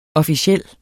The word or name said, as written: officiel